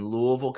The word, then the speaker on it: Louisville: For those of you who may be hearing this and hearing my voice, it is pronounced Louisville, not Louisville, not Louisville, Louisville. – We got to get our buddy Mike on here to tell us. You take the first letter and Ville.